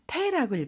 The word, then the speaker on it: 手: 손수라고 하는 한자는 음으로는 슈, 뜻으로는 테라고 읽어요. 우리가 손이라는 뜻을 나타낼 때는 테라고 하죠.